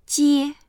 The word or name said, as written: jie1